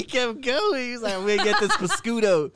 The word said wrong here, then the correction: prosciutto: You mean prosciutto.